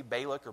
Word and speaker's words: Balak: But then there's another character in the story, and it's a guy named Balak. I think I'm saying that right, B-A-L-A-K, Balak or Balak.